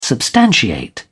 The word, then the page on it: substantiate: British English